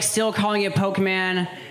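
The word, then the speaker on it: Pokémon: there are still so many people that are pronouncing it Pokemon. Like, we all know it's pronounced Pokemon, okay?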